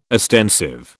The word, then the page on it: ostensive: British English